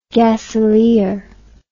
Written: gaselier

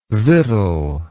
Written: vittle

